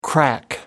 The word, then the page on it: crack up: American English